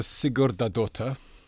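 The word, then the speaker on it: Sigurðardóttir: We had a piece on it in our Cheltenham edition last year by Ursa Sigurdardóttir. It's pretty well pronounced. – There's no one here who can do any better than that, I feel.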